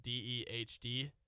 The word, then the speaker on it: Dehd: That's spelled D E H D, Dehd.